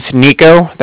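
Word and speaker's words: Niko: Alright, so yeah, my name's Nico, that's N-I-K-O.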